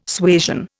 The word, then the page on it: suasion: Indian